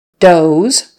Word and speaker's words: does: Another one-syllable word, does versus does, spelled D-O-E-S. And of course, we are not pronouncing it with an S, it's a Z sound. Does, not does, does.